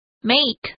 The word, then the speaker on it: make: 大家要注意一下 make 这个字的发音。 A space E 在这边发的是长音 make。